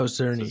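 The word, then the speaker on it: Czerny: I think also, what's his, Henry, how do you say his last name? Henry Cerny? – Oh, Cerny. Yeah.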